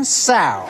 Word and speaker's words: sow: Add to that list 1 sow. – What? – Sow, you know, rhymes with cow.